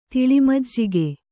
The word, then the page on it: ತೆಳುಮಜ್ಜಿಗೆ: teḷu majjige